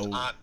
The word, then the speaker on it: aunt: That's what and it's pronounced aunt. There's a U in it.